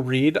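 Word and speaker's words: Reid: I'm on Twitter at Joe Reed.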